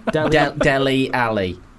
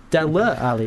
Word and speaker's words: Dele Alli: Dele Alli. – Dele Alli. It's got an E on the end. – Yeah, but it's pronounced Dele.